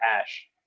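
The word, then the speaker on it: Æ: Like one of the characters is pronounced like Ash.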